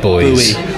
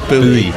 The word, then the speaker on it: buoy: But boys. – Buoy. Yeah, buoy. – Oh, buoy, is it? – Yeah, it is buoy. It's pronounced.